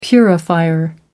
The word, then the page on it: purifier: American English